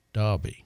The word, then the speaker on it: Derby: Well, the town that I come from in England, Derby, that is with an E, but it's pronounced Derby.